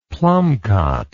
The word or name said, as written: plumcot